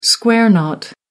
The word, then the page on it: square knot: American English